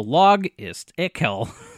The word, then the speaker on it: Logistical: It's called Logistical. Mm-hmm. But it's spelled capital L-O-G, lowercase I-S-T. Capital I-C-A-L. So log is ickel.